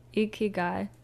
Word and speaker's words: ikigai: Uh, it's interesting enough, the Japanese or specifically the Okinawans called it, if I pronounce it right, ikaigiya. – I think it's ikigai.